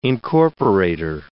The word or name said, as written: incorporator